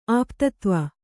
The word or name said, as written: ಆಪ್ತತ್ವ